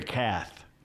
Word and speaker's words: Kath: It's Kath. – What? – Terry Kath, not Keith. – Oh, it's pronounced Kath? It's spelled K-E-I-T-F in a thing I read. – It should be K-A-T-H, right? – I'll go with you.